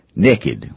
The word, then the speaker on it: nekkid: We change that word and we say naked, which is spelled N-E-K-K-I-D.